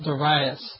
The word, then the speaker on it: Darius: we tend to put the pronunciation on the first of three syllables, Darius, whereas I think the more accurate way to pronounce it in the Greek would put the emphasis on the second syllable, Darius.